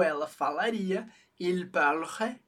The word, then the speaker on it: parlerait: A gente tem parlerait. A gente não pronunciou o NT do final, mas eles têm que estar ali para marcar a distinção singular, plural.